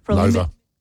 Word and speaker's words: Lova: Lova. – Is it Lova? – See, at the top Lova. Out it says Lova. – Oh, yes. – Almost rhymes with sofa.